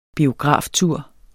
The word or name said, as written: biograftur